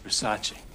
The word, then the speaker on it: Versace: It's a Versace. – It's Versace. – What? – Versace. It's pronounced Versace.